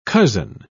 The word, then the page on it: cozen: British English